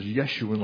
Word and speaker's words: Jeshurun: And what it really means literally, I mean, the pronunciation is Yeshurun, like Yeshua, Jeshurun.